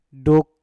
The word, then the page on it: duk: Mpur